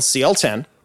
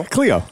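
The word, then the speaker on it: Clio: It was on CL10. For anybody else, it's C-L-I-O. We were talking about pronunciations earlier. Cleo. – Cleo. – But it's actually CL-10 to the locals.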